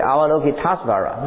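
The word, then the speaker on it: Avalokiteśvara: 你要念的话念成什么 Avalokiteshvara，对，它不是一，它是 A，对不对？ Avalokiteshvara，对不对？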